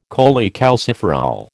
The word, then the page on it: cholecalciferol: American English